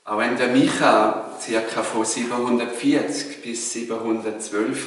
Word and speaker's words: Micha: Liebe Gäste, liebe Gemeinde, Micha, so ist die hebräische Aussprache, hat vor 2750 Jahren gelebt.